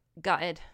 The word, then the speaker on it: gutted: You want to use proper British pronunciation. – Ooh, say it again? – Gutted. – Gutted. – Gutted. – Gutted. – I was gutted. – So you almost skipped the T's.